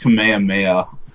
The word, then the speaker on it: Kamehameha: It's pronounced Kamehameha.